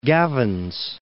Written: Gavins Point Dam

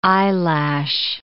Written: eyelash

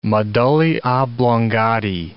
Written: medullae oblongatae

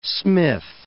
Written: Fort Smith

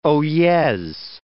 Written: oyez

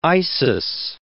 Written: Isis

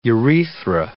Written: urethra

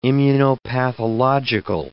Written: immunopathological

